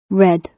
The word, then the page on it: red: British English